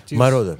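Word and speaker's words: Moroder: Сделаем небольшую паузу, послушаем Джорджа Мородер. Правильно я произношу? – Мородер.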